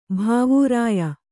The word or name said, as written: ಭಾವೂರಾಯ